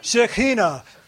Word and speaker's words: Shekhinah: Okay, Shekhinah, Shekhinah.